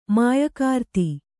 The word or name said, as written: ಮಾಯಕಾರ್ತಿ